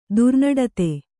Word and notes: ದುರ್ನಡತೆ: Kannada

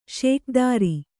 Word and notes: ಷೋಕೀದಾರ: Kannada

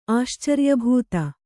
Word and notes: ಆಶ್ಚರ್ಯಭೂತ: kannada

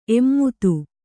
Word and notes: ಎಮ್ಮದು: emmadu

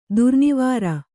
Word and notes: ದುರ್ನಿವಾರ: durnivāra; kannada

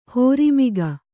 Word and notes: ಹೋರಿಮಿಗ: hōrimiga

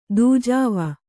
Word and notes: ದೂಜಾವ: Kannada

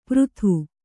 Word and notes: ಪರದು: paradu

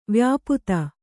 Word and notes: ವ್ಯಾಪ್ತ: vyāpta

